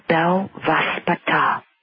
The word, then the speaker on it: Belvaspata: Its name is called Belvaspata. It is a healing modality, and you spell it B-E-L, V for Victor, A-S-P-A-T-A.